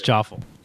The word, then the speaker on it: Jaffle: And it's spelled like waffle, but it's Jaffle. – Yeah, waffle with the J.